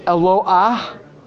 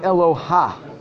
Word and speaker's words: Eloah: Then the el Eloah. Maybe I'll use this as a just very quick point. When we daven Hallel, we should know that it's not Eloha. It's Eloah.